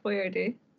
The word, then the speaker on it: Boyardee: Good for Chef Boyardee. I love that his name was actually Boyardee. – Mm-hmm. Yeah. – They spell the name phonetically to keep American tongues from twisting on the Italian pronunciation.